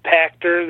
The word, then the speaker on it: Pactor: That's Peter Pachtor. The last name is spelled P-A-C-T-O-R at.